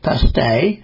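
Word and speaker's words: pastes: Called pasties, pronounced paste, they are made as the same way as those you'll find in Cornwall, right down to the trademark hand-crimped edges.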